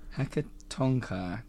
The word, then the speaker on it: Hecatonchire: how do you say that again? – Hecatonchire.